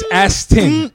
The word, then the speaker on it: Aston: Say it right. It's Aston. – It's Aston. – Oh, it's not Ashton Kutcher. It's Aston, bro.